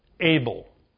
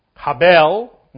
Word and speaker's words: Abel: Abel. That's how you say it in English. Habel in Hebrew, and Abel.